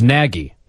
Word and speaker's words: Nagy: Is it Nagy or Nagy, for God's sakes? What is it? – It's Nagy. I think if you're going to pronounce it the same way his East Coast accent does, it's Nagy, but we're in Chicago. – So we're going with the Chicago accent, and it's Nagy.